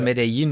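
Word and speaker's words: Medellín: Es gibt den, er hat ja in Medellin spricht man es, glaube ich, ne? – Ja, ja. – Also Medellin. – Die Kolumbianer sagen Medellin.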